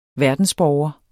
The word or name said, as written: verdensborger